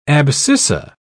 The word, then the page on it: abscissa: British English